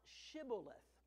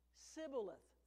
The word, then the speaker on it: Shibboleth: And if he said no, they would say to him, "Say now Shibboleth." But he said Sibboleth, for he could not pronounce it correctly.